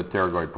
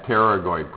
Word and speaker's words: pterygoid: Okay, pterygoid muscles that attach to the pterygoid process. All right, so I think even though it's spelled with a P, I want everybody to say what this process is.